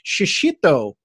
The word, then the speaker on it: shishito: But right there at the top of your page, as we're recording this, you have something called a shishito pepper. So first, I'm sure I'm just butchering that name. But second, they look absolutely delicious. – No, you did a good job pronouncing.